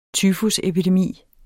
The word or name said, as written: tyfusepidemi